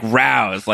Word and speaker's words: Rows: Yeah, it's spelled R-O-W-S, though. It's like rouse, like when you're like having a row.